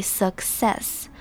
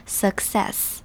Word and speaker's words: success: 那接下来我们来看 success 这边有两个 C 连在一起，那这边连在一起的两个 C 很特别，它要分开发音，所以是拆成 suck 跟 success 这样子。第一个 C 是读成 k 的声音，但是第二个 C 则是读成像 S 一样 s 的念法，所以 success。虽然两个 C 连在一起，但是它们的发音是不同，必须分开发音 success。